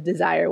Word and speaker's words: DSIRE: And so after tax credits, my next go-to recommendation for clients who are looking for funding is the Desire website. But you got to be careful how you search for it because it's not spelled like the emotion. It's actually spelled D-S-I-R-E.